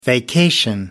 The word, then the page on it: vacation: American English